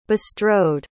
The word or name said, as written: bestrode